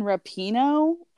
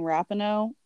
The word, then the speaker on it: Rapinoe: So, I recently found out, I think I've been mispronouncing her name. I heard that it's Megan Rapinoe, and I've been saying Rapinoe, whatever.